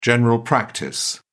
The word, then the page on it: general practice: British English